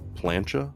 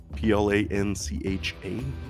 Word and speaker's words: plancha: Spaniards adopted these grills with the name, I'm going to butcher this, plancha, P L A N C H A, plancha, which means metal plate, metal plate.